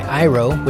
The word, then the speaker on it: Iroh: A new song that was just posted the other day by Iroh, which I just learned how to pronounce that. I couldn't tell if it was I-R-O-H or Iroh, but I was straightened out by some fellow music podcasters.